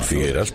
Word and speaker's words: Figueres: Ya que quieres ser tan políticamente correcto con los nombres en catalán, Figueres, puedes decir Figueras porque es una E abierta. Y cuando se pronuncia en catalán, se pronuncia igual que en castellano.